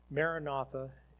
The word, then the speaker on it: Maranatha: And it has two pronunciations. Maranatha as a word together. So Maranatha means come, Lord. Okay. If you take and you, there's another way which it can be done both ways in this verse. It's interesting because it really doesn't matter to a certain extent, because the other way is Maran or mera, and then nata. So it splits it. And so that's the other pronunciation.